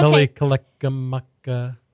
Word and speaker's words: Mele Kalikimaka: Mele Kalikimaka, which is Merry Christmas in Hawaiian. Hawaii. Yeah, the the, the song. Yeah. Okay. Mele Kalikimaka.